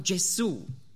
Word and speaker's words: Gesù: Aber auf Italienisch ist das nicht die Aussprache, die der Sprache entspricht. Und darum sagt man Gesù.